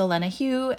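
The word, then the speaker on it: Olena Heu: My name is Olena Hugh, and if you'd like to follow along on our journey, The Best Hawaii is on all social media, along with myself.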